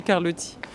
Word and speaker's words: Carlotti: Bah, ça dépend si on est italien, corse ou français. Il y a plein de manières de prononcer. C'est comme de prononcer les noms des artistes à l'américaine ou pas. En fait, en France, on francise quand même beaucoup la prononciation. Donc moi, je dis Carlotti.